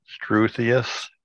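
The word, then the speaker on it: struthious: Strithius. – Struthius is S-T-R-U. – Struthius.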